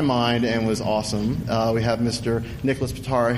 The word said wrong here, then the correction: Patera: here. – Yeah, Patera. – Sorry. – Wait, it's Patera? – Yeah, I screwed my name up. – Doesn't know why it's pronounced Patera.